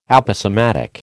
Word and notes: Aposematic: American English

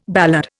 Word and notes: ballad: Indian

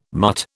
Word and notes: mutt: British English

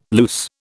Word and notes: loose: British English

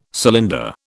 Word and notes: cylinder: British English

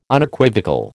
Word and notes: Unequivocal: American English

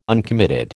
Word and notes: uncommitted: American English

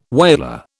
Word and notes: whaler: British English

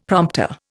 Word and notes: prompter: Indian

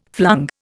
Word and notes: flung: Indian

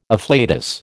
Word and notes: afflatus: American English

